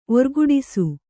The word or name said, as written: ಓರ್ಗುಡಿಸು